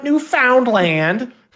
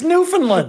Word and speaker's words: Newfoundland: How does nobody on this show know how to pronounce Newfoundland? It's what? not Newfoundland. It's Newfoundland. What my response to you though was like, yeah, clearly this single word is said as three separate words.